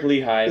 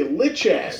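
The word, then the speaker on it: Lichaj: Eric Lehigh. – Is it Lehigh? – Yeah. – That's how you pronounce that? – Yeah. – I was going to say Lichash.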